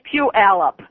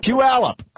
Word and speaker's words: Puyallup: It's Puyallup. – Puyallup.